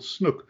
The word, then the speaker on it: snoek: And I need to remind anybody who's listening to me who is from America, there is a fish over here called snook. It's a very different species that Richard and I are talking about. The one that we're talking about is spelt S-N-O-E-K,